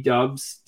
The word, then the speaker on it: Doubs: I mean, like, and again, am I not pronouncing his last name right? – Doubs, I think that's how you say it, actually.